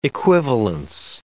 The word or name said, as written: equivalence